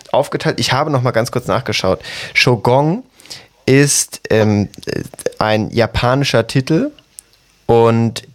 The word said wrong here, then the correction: Shogun: Ganz kurz, das heißt Shogun. Genau.